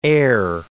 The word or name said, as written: e'er